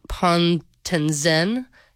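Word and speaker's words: Pontanezen: When he requested them for his dangerously muddy posting at Camp Pontenzen in France, am I saying that right, Liam? – That sounds about right.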